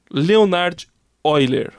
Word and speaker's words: Leonhard Euler: Leonhard Euler.